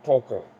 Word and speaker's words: Tolkien: Tolkien.